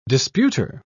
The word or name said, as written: disputer